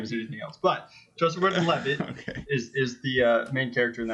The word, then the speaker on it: Gordon-Levitt: has adamantly argued that his name is Joseph Gordon-Levy, and you don't pronounce the T's, and... Tyler and I have just been arguing with him until we're blue in the face that it's Joseph Gordon-Levitt, and he just let slip how to pronounce it correctly.